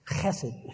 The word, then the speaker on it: chesed: Now, in Hebrew, that's a little word that's pronounced chesed, excuse me. That's how they talk. You never wanted to have a lot of dinner conversation in those days. Many guttural sounds.